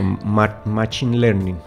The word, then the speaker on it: Machine Learning: Machine Learning.